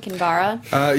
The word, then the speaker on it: Kinvara: Is that how we say her name? Kinvara? – Yes, if you want to know the full name.